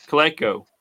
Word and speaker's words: Coleco: I thought it was Colco. – Colco? – Is it Coleco? Is Coleco? – Yeah. It was Colco. – No, Coleco.